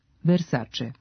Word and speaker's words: Versace: Iako se prezime poznate italijanske porodice Versace čita sa ć, ovakav izgovor nema uporište u pravopisu. Prema pravilima transkripcije, italijansko c se ispred vokala e i i čita kao č, Versace.